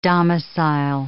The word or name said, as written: domicile